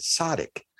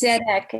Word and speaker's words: Tzedek: Now, I read it because I don't read fluent Hebrew, but I read it as Tzaddik, which is like the name for a great rabbi, a great generational rabbi. But I think it's pronounced differently. Tzedek? – It's Tzedek, and it means justice. Yes.